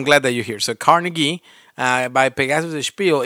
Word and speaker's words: Carnegie: I'm surprised because I only have two more, but I'm surprised you didn't mention this one, Carnegie. I hope, is that how you say it, Carnegie? – Carnegie. Yeah, like a hard G.